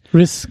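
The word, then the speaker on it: Riz: So, und dann haben wir noch ein paar andere Darsteller, allen voran natürlich Riss Ahmed, spricht man den, glaube ich, aus. Ich weiß nicht genau, wie man es ausspricht. – Riss, genau.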